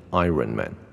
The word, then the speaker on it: Iron: 啊、呃，比如说钢铁人，钢铁人，大家很多人真的超级多人会念成 Iron Man。我不知道你是不是这样念 Iron Man，Iron，为什么会有卷舌呢？是因为你们觉得有个 R，OK？